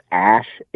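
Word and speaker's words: Æ: Yeah, I heard Elon Musk on Joe Rogan's podcast, and he was saying the AE, like, which is pronounced Ash, is his wife's idea or his girlfriend's idea or whatever.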